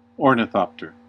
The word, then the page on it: ornithopter: American English